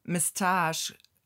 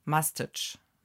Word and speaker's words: moustache: ich sage jetzt ist einmal auf, im britischen Englisch, da wird es Mistage ausgesprochen. – Mhm. – Wenn ich es richtig habe. Und das äh, äh, amerikanische ist Mustache. Must, mustache.